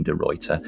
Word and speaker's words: de Ruyter: And congratulations on pronouncing Geraldine's last name correctly. That is a feather in your cap. – I've noticed we get that with American guests, actually. It's not the first time someone's gone, oh my God, you pronounced it right, but we're surrounded by European names here, so I have an unfair advantage.